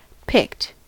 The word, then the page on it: picked: American English